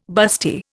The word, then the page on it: busty: Indian